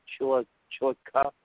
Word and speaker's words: Chillicothe: Uh, they'll be at Atomic Speedway in uh, Chilochi, Ohio. Did I say that right? Chillicothe, Chillicothe, Ohio, at Atomic Speedway uh, for $12,000 to win.